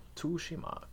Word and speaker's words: Tsushima: Tsushima, Ghost of Tsushima. Tsushima. That's more like, you do have to put some kind of emphasis on the T. I think it's Tsushima.